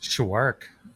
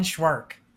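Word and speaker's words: Szwarc: And It's Schwark, isn't it? It's very close to shark. – Oh, we just did this episode. It's Jean Schwark.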